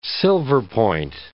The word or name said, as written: silverpoint